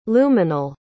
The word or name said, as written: luminal